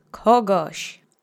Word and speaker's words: kogoś: Accent is on the first syllable. Zmieszać. Zmieszać. Zmieszać. Great. The next word is kogoś, which means someone.